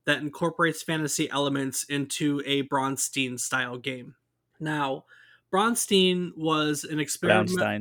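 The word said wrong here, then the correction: Braunstein: Brown. You don't have to roll your R, but it's brown, like the color. Brownstein. There you go.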